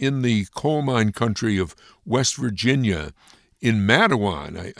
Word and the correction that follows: Matewan: I can, and it's actually pronounced Matewan. I think there's a Mattawan, New York, and a lot of people get it confused.